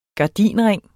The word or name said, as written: gardinring